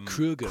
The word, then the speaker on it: Kroeger: As it turns out, Nickelback's Chad Kroeger's, we've been pronouncing his last name wrong this entire time. Did you hear about this? – Kruger. – Kruger. – Okay. – Yeah, it's Kruger.